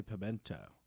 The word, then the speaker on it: pimiento: Why is it spelled like that, but people say pimento? – Because the word is pimento in Spanish.